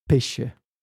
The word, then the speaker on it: pesce: Pesce.